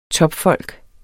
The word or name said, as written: topfolk